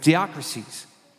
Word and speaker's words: diakrisis: That's a Greek word, how you pronounce it, diakrisis.